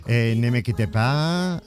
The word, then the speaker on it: Nemequitepa: Nemequitepa, lo he pronunciado bien, ¿no? – Muy bien.